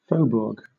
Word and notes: faubourg: British English

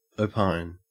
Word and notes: opine: Australian English

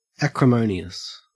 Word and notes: acrimonious: Australian English

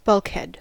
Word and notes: bulkhead: American English

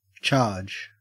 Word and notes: charge: Australian English

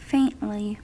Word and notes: faintly: American English

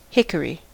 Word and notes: hickory: American English

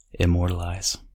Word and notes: immortalize: American English

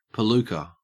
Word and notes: palooka: Australian English

